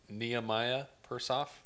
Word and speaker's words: Nehemiah Persoff: The actor playing Sam Bielinski here is I believe it would be pronounced Nehemiah Persoff. – Yeah, I would say that.